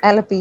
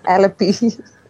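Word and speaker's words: Alleppey: Is that how you say it? Alapuja? – Not at all. But Alapura, yeah. – Good effort. – Alapi. You can say like Alapi.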